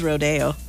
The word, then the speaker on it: rodeo: Is it rodeo or rodeo? – Oh, now, if you're in Salinas, it is rodeo.